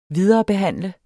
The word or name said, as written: viderebehandle